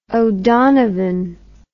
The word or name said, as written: O'Donovan